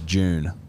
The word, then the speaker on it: Dune: Like I saw her in Dune or June. – Yeah. – Dune. I don't know which way you're meant to pronounce it. – Well, we're not Yank, so it's June.